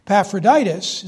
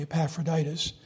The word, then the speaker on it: Epaphroditus: It probably should be pronounced the latter way, because the emphasis should be on the last syllable in Greek, but I like the English way, Epaphroditus.